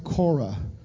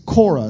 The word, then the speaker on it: Korah: The last one, talking to the church, the spirit of Korah. In the Old Testament, it's spelled K-O-R-A-H. That is how the Hebrew would have spelled it. But it is Korah. It's not Kor, but it's Korah.